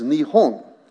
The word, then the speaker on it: Nihon: Word Japan, of course, is an American or English term that we use, but the Japanese pronunciation is Nihon.